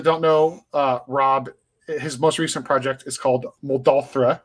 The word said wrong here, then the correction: Moldrotha: right? That's I'm saying it correctly. – It's actually Moldrotha.